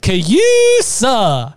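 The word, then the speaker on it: C-USA: or as they liked to call it, and this is the way it's pronounced, Cayusa.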